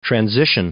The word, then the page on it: transition: noun